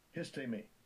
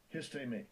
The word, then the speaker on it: histemi: Histemi. Histemi.